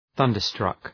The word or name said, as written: thunderstruck